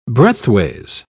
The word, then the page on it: breadthways: British English